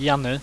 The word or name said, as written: jany